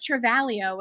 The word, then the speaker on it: Travaglio: My last name's Travaglio with a silent G in there.